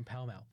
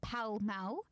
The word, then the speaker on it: Pall Mall: Did you just call it Pall Mall? – Pall Mall, yeah, that's how it's pronounced. – I always called it Pall Mall. – No, it's Pall Mall.